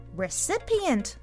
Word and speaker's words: recipient: それではみんなで今日は recipient を言ってみましょう。 recipient E と I の発音の違い要注意。